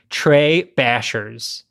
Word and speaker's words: Tres-Bashers: so I'm going to assume that it's pronounced Tres Bashers. And that's TRES-Bashers, as in like you're bashing something.